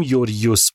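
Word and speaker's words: یوریوس (Γεώργιος): تلفظ درست اسمش که گفتم یوریوس بود که چون سخت بود بهش گفتیم جورجیوس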